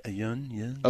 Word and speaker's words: Yeun: Is it Yen? Is that how you pronounce it? – Yeun.